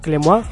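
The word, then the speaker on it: Clément: Así que nada, eh, le damos al play y escuchad esa entrevista que hicimos al jurado, como eran eh, Clement, que se pronunciaba Clemois, aunque de allí nos costará un montón el pronunciarlo,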